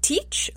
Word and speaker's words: Teach: So sometimes Blackbeard's name is written and pronounced Teach, like T-E-A-C-H, and other times it's written and pronounced Thatch, like T-H-A-T-C-H, like a thatch roof.